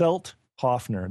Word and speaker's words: Selthoffner: It's Selthoffner. That's Selthoffner. And it's kind of heavy on the T. I made sure I had him, inform me on how I should pronounce that.